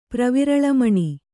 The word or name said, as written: ಪ್ರವಿರಳಮಣಿ